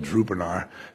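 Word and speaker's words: Durupınar: By the way, it's spelled Drupanar. Drupanar, that's D-U-R-U-P-I-N-A-R, Drupanar, in Turkey.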